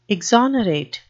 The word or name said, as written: exonerate